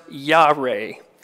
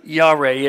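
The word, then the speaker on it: Yareh (יָרֵא): The Hebrew word that's used in the Bible for awesome is pronounced Yareh, Y-A-R-E, Yareh.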